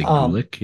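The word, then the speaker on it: Güllich: And then Wolfgang, I'm probably gonna butcher this, but Gulich. – I would say Gulick, yeah.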